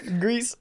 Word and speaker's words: Gris: How did you guys find the uh, controls for Gree on your phone? Uh, now even I'm mispronouncing it. right, right. What was it? Gris. Gris. – Yeah, uh, Gris.